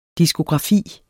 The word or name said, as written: diskografi